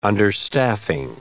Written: understaffing

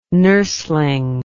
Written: nurseling

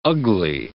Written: Ugli

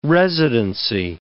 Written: residency

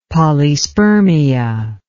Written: polyspermia

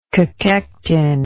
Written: cachectin

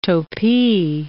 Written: topi

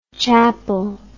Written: chappal

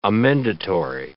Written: amendatory

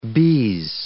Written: BS

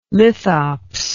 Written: lithops

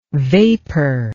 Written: vapour

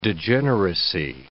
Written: degeneracy

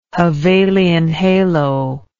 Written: Hevelian halo

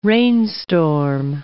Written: rainstorm

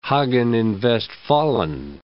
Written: Hagen in Westfalen

